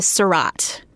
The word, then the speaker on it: Surratt: It is Surratt. – Okay. How do you spell that? – S-U-R-R-A-T-T.